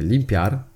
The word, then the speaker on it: limpiar: El limpiar es una palabra aguda de dos sílabas. Las palabras agudas son aquellas acentuadas en la última sílaba.